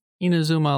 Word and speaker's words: Inazuma: I've heard, of course, one of their biggest franchises, Inazuma, how do you pronounce it? – Inazuma 11.